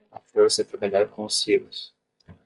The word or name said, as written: silos